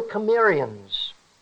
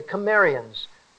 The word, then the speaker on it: Cimmerians: We call them the Cimmerians. The Cimmerians, spelt with a C but pronounced like a K.